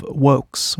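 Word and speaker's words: Vōx: Her name is Sarah, but she goes by the artistic name of Wokes, which is spelled V-O-X, and the O has a little flat line accent on it.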